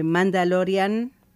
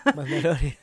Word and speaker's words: Mandalorian: Porque, como te decía, ya está disponible la preventa para el estreno de Mandalorian. ¿Lo digo bien? – Hasta ahora sí. – Mandalorian.